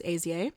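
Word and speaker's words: Ie: This is perhaps a unique phenomenon, but my last name, Easy, I-E, is pronounced Azie.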